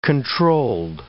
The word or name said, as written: controlled